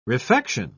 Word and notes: refection: British English

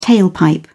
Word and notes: tailpipe: British English

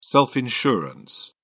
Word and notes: self-insurance: British English

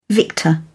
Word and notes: victor: British English

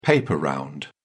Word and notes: paper round: British English

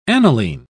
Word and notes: aniline: British English

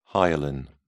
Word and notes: hyaline: British English

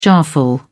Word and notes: jarful: British English

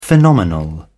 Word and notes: phenomenal: British English

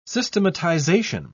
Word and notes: systematisation: British English

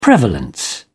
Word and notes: prevalence: British English

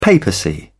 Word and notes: papacy: British English

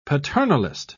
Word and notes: paternalist: British English

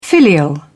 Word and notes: filial: British English

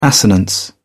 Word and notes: assonance: British English

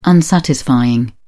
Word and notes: unsatisfying: British English

